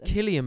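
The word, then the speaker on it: Cillian: Gillian Murphy was... – Killian Murphy. – Is it Killian? – You get that name wrong every time.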